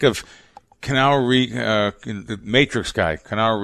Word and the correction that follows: Keanu: Am I saying his name right? Canal Reeves? – Keanu Reeves. – Keanu. Keanu Reeves,